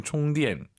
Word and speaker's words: 充电: 비슷하지 않나요? 발음이 충전, 충전, 총디엔, 총, 충분하다 할 때 그 총입니다.